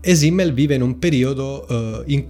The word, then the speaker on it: Simmel: Questo filosofo di cui parleremo oggi è Georg Simmel,